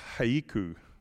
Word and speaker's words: haiku: In Japan, they have a haiku. Is that how you say it? Haiku, thank you.